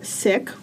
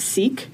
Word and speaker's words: Sikh: So you are Sikh, which I think in America a lot of people pronounce Sikh.